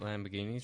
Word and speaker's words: Lamborghini: Well, I mean, they might do what Lamborghini tries to do with their electric vehicle and have the speakers playing some car noises.